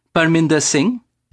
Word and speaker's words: Parminder Singh: Parminder Singh.